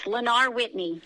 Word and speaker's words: Lenar Whitney: Yes, it's Lenar Whitney, yes.